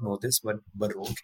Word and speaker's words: Baroque: I would say I listen to this genre of music a lot, which is, I think it's pronounced Baroque. Baroque? – Mm-hmm. Yeah, yes. Baroque.